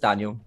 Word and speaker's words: Daniel: But yeah, no, it's just Daniel, just as you would say it.